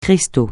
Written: cristal

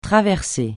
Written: traverser

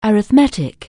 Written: arithmetic

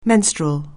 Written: menstrual

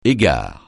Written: égard

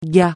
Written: gars

